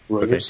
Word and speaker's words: Reuters: One for, how do we pronounce this? Reuters? – Yep. – Reuters, okay.